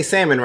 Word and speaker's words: salmon: So I was talking to Marcel yesterday, and as Marcel is a chef, you would think he'd be able to say salmon right. But he kept pronouncing the L, and I was like, bitch, why are you pronouncing the L? It's silent.